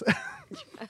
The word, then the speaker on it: Thomas: Well, I don't know if I should pronounce it Thomas with the hard T or with a, if it's spelled with the TH, is it Thomas? – Thomas.